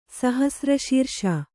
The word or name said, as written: ಸಹಸ್ರಶೀರ್ಷ